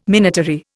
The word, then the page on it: minatory: Indian